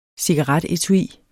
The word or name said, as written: cigaretetui